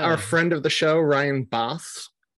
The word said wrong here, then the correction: Bosch: Mm-hmm. Bosch. I never know how to pronounce his name. Ed Bosch. Yeah, there we go.